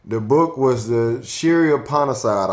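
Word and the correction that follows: Śrī Īśopaniṣad: I think it's Shri Ishopanishad.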